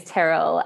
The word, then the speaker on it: Tyrrell: So Squirrel Sisters, so our surname is Tyrrell.